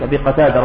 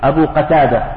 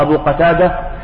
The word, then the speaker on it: قتادة: هذا الحديث الأول، حديث أبي قتادة رضي الله تعالى عنه وأرضاه، بعض الناس يعني ينطقه ويتلفظ به خطأ، يقول أبي قتادة، سمعت بعض الخطباء يقول أبي قتادة، هذا غير صحيح. أبو قتادة بفتح القاف، بفتح القاف، أبو قتادة،